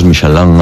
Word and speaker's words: Michelin: Ya, pero ¿tú cómo lo pronuncias? – Me cuesta ser tan tontorraco, ¿no? Dice Michelin. – ¿Michelin? – Yo digo Michelin. – Vale. – Y cuando vas a una gala, pues escuchas Michelin a veces.